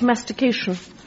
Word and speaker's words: mastication: And it's to do with correct mastication, if I may use that word, on Radio 4. Sorry, Gavin, three syllables. – Four syllables. – Actually, four, yes. Sorry, my maths was crap.